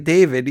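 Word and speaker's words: Davad: No, they pronounce it like David, even though it's spelled differently.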